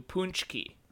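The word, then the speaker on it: pączki: Punch. Like punch, but with a weird U. Punschke.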